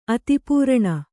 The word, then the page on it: ಆದಿಪುರಾಣ: ādipurāṇa